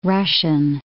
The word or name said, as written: Russian